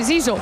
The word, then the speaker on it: Zizo: Hem de dir Zizo, has dit? – Sí, Zizo. – Zizo, eh? No Zico, eh? – No, Zizo.